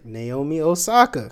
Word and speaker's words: Naomi Osaka: Naomi Osaka.